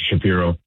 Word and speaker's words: Shapiro: So I didn't get any major league offers, but I fortunately was, Ross Atkins and Mr. Shapiro. I'm saying that correctly. – Yeah, Mark, it is.